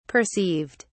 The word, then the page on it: perceived: British English